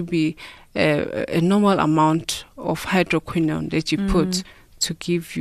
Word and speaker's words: hydroquinone: hydroquinone? Is that how you say it? – Yes.